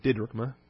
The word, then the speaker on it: didrachma: The Greek wouldn't use a long I sound. It would use a shorter I sound, didrachma. accent on the first syllable.